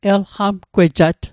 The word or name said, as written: elqamgwejat